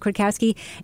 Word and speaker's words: Kwiatkowski: I actually happen to know that that's how the name is pronounced, even though it has many more letters than that, but it's pronounced Kwiatkowski.